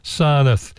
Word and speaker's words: Sarnath: Sarnath, S-A-R-N-A-T-H. That's our next stop. We've just 8 kilometres from Varanasi, and it's one of the most important Buddhist religious sites on earth, Sarnath.